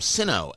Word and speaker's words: Sinno: A-B-D-U-L-K-A-D-E-R, last name Sinno, S-I-N-N-O.